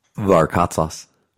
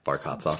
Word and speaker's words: Varkatzas: Varkatzas. – Varkatzas. – Yeah. – Really? – Yeah, Varkatzas. – Spell it. – V-A-R-K-A-T, Z as in zoo, A S as in Sam.